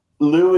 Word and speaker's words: Loup: Lou is French for wolf. So it's kind of like a sarcastic name. – Oh, I didn't know that. That's cool. – Yeah, my wife's French. It's spelled L-O-U-P. We took the P off, but yeah, he's a good boy.